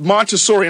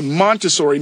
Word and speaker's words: Montessori: So at this Montessori, and I was mispronouncing it, so it's the Montessori, Maria Montessori School, and I appreciate you, Demi, for that.